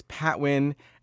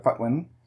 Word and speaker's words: Patwin: The native pronunciation would be closer to Putwin. But yeah, I guess when you read the word in English, it looks like Patwin, so people say Patwin.